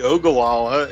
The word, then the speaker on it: Ogallala: He is located in, and I'm going to butcher this, it's Ogallala, Nebraska. Did I get it close, on the pronunciation? – It would be Ogallala, and I'm in Grant, actually, is where I live.